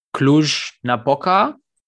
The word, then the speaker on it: Cluj-Napoca: April ähm, in Cluj-Napoca, wenn ich das richtig ausgesprochen habe.